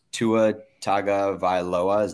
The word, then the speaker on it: Tua Tagovailoa: Actually that's not true because Tua Tagovailoa, did I say that right? – Yeah.